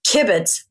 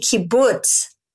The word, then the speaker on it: kibbutz: So, Judy, first I'm going to say, I can understand why you're pronouncing it that way. And I love it. The creativity kibbutz, but it's actually the creativity kibbutz.